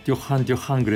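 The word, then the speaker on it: Duran Duran: 파리에 갔더니 어느 고등학교를 취재 갔는데 파리 여고생들이 전부 듀란 듀란을 듣는데 뭐라고 부르냐면 듀한 듀한 그래요. 그저 불어 발음으로.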